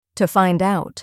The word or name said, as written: to find out